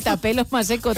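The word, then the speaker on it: Thapelo Maseko: No, sí, Tapelo Maseko, tal cual. Lo he leído tal cual se escribe.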